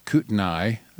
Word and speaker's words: Kootenai: But if you want to know who to vote for, go to Kootenai GOP, K-O-O-T-E-N-A-I. Kootenai, as outsiders often call it, Kootenai. It's pronounced Kootenai.